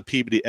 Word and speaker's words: Peabody: There is a town next to Salem that is spelled P-E-A-B-O-D-Y, and those words are P and body. And when you put them together, it's Peabody. I didn't make the rules. That's how it's pronounced.